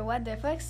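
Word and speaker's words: Ouate de phoque: En fait, euh, What the Fuck, euh, ça s'écrit O-U-A-T-E, comme de la Watt, puis euh, P-H-O-Q-E, comme l'animal le fuck.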